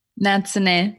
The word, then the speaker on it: Natsune: Natsune.